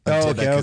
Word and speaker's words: Dybbuk: Have you heard of I can't pronounce this, D-Y-B-B-U-K, the Dybbuk box? – Dybbuk.